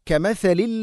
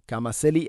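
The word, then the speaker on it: كَمَثَلِ: കമസലിൽ ലദീന മിൻ ഖബ്ലിഹിം. കമസലി അല്ല കമസലി, ഫാ ഉച്ചാരണം പഠിക്കുക. കമസലി, നാവിൻ്റെ അറ്റം മുൻപല്ലുകളുടെ ഇരു നിരകൾക്കിടയിലൂടെ അല്പം പുറത്തോട്ട് വരണം.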